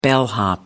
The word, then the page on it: bellhop: British English